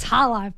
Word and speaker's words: challah: And it's challah.